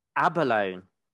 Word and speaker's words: Abalone: Abalone, overrated or underrated? – You pronounced it right, even.